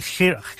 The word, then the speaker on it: Janeiro: Janeiro. Is it Rio de Janeiro? We call it Rio de Janeiro. – You know Spanish. – You pronounce the J as a sort of Y.